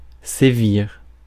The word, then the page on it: sévir: /se.viʁ/; France